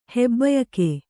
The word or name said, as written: ಹೆಬ್ಬಯಕೆ